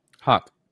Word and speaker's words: Houck: I want to know before I even play the video, how do you guys spell uh, pronounce a word spelled H O U C K? – Hawk. Hawk. – Okay. So the guy in the military, his his last name was Hauk, and that's how it was spelled.